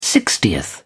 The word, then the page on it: sixtieth: British English